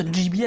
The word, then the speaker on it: Ghibli: On le connaît nous comme ça, alors ça se prononce Ghibli à la japonaise. – Ghibli, on est d'accord.